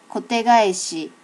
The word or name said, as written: kote gaeshi